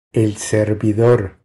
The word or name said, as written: El servidor